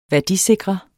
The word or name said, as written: værdisikre